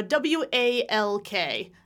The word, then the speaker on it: walk: I'm going to go out for a W A L K. Do I say that L there? Walk. Walk, Walk right? Yeah. No, no, right? It's a silent L as well, right?